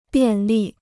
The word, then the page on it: 便利: biàn lì